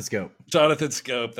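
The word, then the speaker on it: Schoop: It rhymes with loop. No. Mope. – Jonathan Scope.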